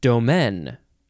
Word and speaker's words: domaine: Now, the previous word, previous episode, they do have the same pronunciation, domain, but this the second possible pronunciation for both is different because this one is spelled D-O-M-A-I-N-E. Domaine.